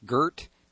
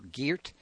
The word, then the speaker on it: Geert: And when we get back, let's get to, I guess his name is pronounced Geert or Geert, G-E-E-R-T, Wilders, a member of the Dutch Parliament.